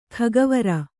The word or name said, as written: ಖಗವರ